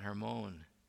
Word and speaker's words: Hermon: Mount Hermon, as the Jews pronounce it, the large mountain that stands as the border between Israel and the land of the country of Lebanon to the north.